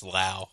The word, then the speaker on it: Lowe: Actually, this guy, it's not Pepio, it's Lau. I've never heard of anyone spell it L-O-W-E. – And they call it Lau.